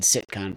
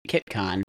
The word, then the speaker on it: CITCON: because like KitCon, and I pronounced it wrong, I said CITCON, but KitCon, it was its first one looks like it was in Chicago in 2006.